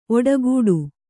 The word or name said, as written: ಒಡೆಗುಟ್ಟು